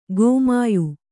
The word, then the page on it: ಗೋಮಾಯು: gōmāyu